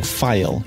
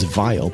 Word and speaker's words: phial: Now, I keep saying phial. You may think it's vial, but it is spelled P-H-I-A-L.